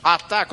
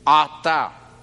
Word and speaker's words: ata: Ata con ayin. Si es con alef, significa tú. Ata, tú. Ata. Ahora. Por eso hay que pronunciarla. Ayin, ayin. Aquí abajo.